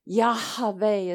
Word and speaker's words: Yahweh: And the name Jehovah, Yahweh is how they pronounce it in some ancient ways.